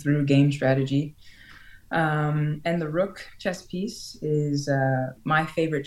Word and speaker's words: Rokh: It's spelled R-O-K-H, but it is pronounced Rokh.